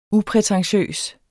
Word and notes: uprætentiøs: [ ˈupʁεtεnˌɕøˀs ]; [ -pʁεtɑŋˌɕøˀs ]

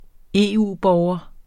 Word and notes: EU-borger: ˈeˀˌuˀˌbɒːwʌ; ˈeˀˈuˀ-

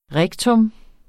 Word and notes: rektum: ˈʁεgtɔm; ˈʁagtɔm

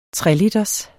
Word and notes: 3-liters: treliters

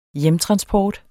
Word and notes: hjemtransport: dansk

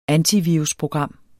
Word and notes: antivirusprogram: ˈanti-

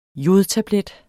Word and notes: jodtablet: [ ˈjoð- ]

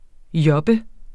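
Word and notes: job: Danish

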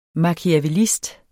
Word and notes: machiavellist: mɑkiavεˈlisd; ma-

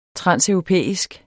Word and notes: transeuropæisk: [ ˈtʁɑnˀsœwʁoˌpεˀisg ]; [ -εwʁoˌpεˀisg ]

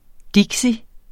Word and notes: dixie: [ ˈdigsi ]; [ ˈdegsi ]

